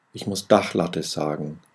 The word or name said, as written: dachlatte